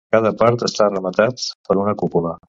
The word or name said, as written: rematat